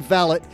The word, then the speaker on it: valet: No, I said it right. That's how they say it in England, valet.